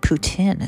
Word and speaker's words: poutine: I think poutine is how you say it.